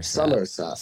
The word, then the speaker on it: Somerset: Somerset. Is that is that how you say it? Somerset. Somerset. – Somerset. – Somerset. Yeah. Yeah, that's it it's sort of like countryside, beautiful landscape world.